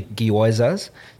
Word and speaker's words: gyoza: Well, so you know you have, I never can pronounce it, but the gyozas. So that's the you don't have to do the Y. I think it's just gyoza.